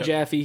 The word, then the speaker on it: Jaffe: I think it's Jaffe or Jaffe. So that you pronounce the E. David Jaffe, he tweeted out and he said, here's useless tweet, but if I don't say something, I will explode.